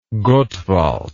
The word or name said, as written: Gottwald